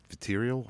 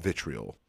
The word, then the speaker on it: vitriol: Or I typed in vitriol. I always say this wrong. – Vitriol. – I always call it vitriol. I don't know why.